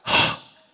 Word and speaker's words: Heh: H-E-I. Heh. It's actually pronounced Hah.